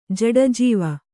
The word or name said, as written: ಜಡಜೀವ